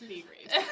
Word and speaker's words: Ley Greaves: I have some rapid-fire questions for you, but before we go into that, I thought we pronounced the second name Ley. So for the past year and a half, I have been calling you Laura Ley Greaves. – Common misconception. It's Ley Greaves.